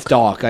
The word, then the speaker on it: Dach: Dach trade done. – Is it Dach or Doc? – It's Doc. I just mispronounced it right there. Kirby Doc.